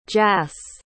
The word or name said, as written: jas